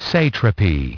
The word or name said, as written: satrapy